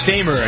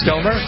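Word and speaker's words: Stoermer: Mark Stamer, is that how you pronounce his name, Stomer. – Bass player? – It's Stomer.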